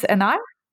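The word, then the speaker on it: Énard: His last name is Enard, spelled E N A R D, and his first name is Matthias, but uh, that's the German pronunciation. Matthias, I don't know. what is the correct? – Uh, I, I, would say it as Matthias Enar. Matthias Enar.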